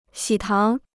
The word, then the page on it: 喜糖: xǐ táng